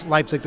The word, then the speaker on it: Leipzig: And I'm happy you pronounce Leipzig the same way as me.